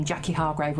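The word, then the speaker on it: Jacqui Hargrave: If you go onto the Just Giving page and look up my name, Jackie Hargrave, which is spelt JACQUIHARGRAVE.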